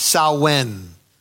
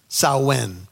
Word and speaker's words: Samhain: And so they began to celebrate a holiday called, it's spelled Samhain, But as you read a little bit more on this, they say it's pronounced Samhain, Samhain.